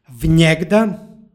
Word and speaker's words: vnjegda: To je složenica od jegda i predloga koji su spojeni, između njih se umeće n koje se obavezno jotuje. Stoga je izgovor vnjegda.